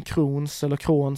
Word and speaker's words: Crohn: Han har ju den här kroniska tarmsjukdomen Crohn som man uttalar det.